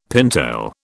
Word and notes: pintail: British English

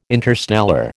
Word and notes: interstellar: American English

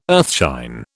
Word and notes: earthshine: British English

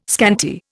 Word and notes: scanty: Indian

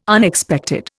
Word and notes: Unexpected: Indian

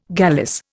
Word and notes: gallice: Indian

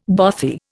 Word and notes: bothy: Indian

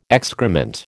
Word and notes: excrement: American English

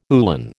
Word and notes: uhlan: American English

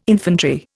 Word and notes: infantry: Indian

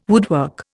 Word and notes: woodwork: Indian

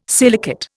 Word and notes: silicate: Indian

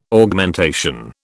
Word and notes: Augmentation: British English

